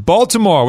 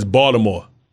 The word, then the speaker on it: Baltimore: Let's go to Baltimore. What's up? – It's Baltimore. – Baltimore? – You don't pronounce the A. It's not Baltimore. It's Baltimore. – Baltimore.